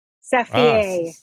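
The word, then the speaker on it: Saphier: It's not spelled like the stone. It's S-A-P-H-I-E-R. Yeah. That's the way my mother-in-law pronounces it.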